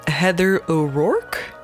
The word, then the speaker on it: Heather O'Rourke: De bekendste van de sterfgevallen van de Poltergeist geschiedenis is toch wel Heather O'Rourke. kan het meisje haar naam jammer genoeg niet correct uitspreken. Wil jij dan een keer proberen voor mij? – Heather O'Rourke? – Ja, inderdaad. Dat is mijn dikke tong die in mijn weg zit.